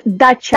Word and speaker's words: dacha: If I remember correctly, there is something in Russia called dasha. Am I pronouncing that right? It's like a summer house sort of thing, like outside of it's dacha.